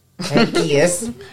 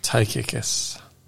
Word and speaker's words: Tychicus: Tychicus. – Tychicus.